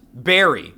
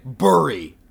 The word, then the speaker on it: berry: The word berry is pronounced berry slash berry, not fucking burry.